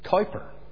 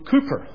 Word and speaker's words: Cowper: It was William Cooper, and it looks like it's spelt and therefore should be pronounced Cowper. But Cooper, apparently, was the pronunciation.